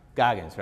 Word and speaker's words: Goggins: How do you say his last name? – Goggins. – Goggins, right? With a G?